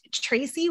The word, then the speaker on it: Tracey: This is your host, Tracy Watserino, and that is spelled T-R-A-C-E-Y. My dad always said T-R-A-C-E-Y is the only way to spell Tracy.